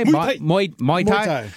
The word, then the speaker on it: Muay Thai: I don't even know, like, is it pronounced like Muay Thai? – Muay Thai.